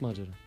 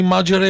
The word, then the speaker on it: Маджера: Кой от Маджера? Ти знаеш ли кой още от Говедарци? – Абе, верно ли Маджера е правилното ударение? – Разбира се. – Маджера, разбира се.